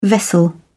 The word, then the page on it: vessel: British English